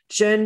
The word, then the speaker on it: Zhen: Jun is spelled Z-H-E-N, sorry, Z-H-E-N for Americans.